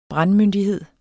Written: brandmyndighed

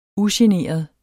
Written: ugeneret

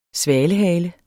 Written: svalehale

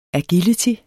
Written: agility

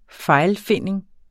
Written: fejlfinding

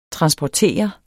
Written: transportere

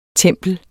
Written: tempel

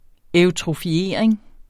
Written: eutrofiering

